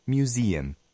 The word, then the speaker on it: museum: La E suena como I y se acentúa. La segunda U suena como E no acentuada. Repita. Museum.